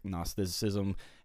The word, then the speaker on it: Gnosticism: which is spelt with a G in case you don't know G N O S T uh, Gnost like that Gnostic Gnosticism, however you want to say it.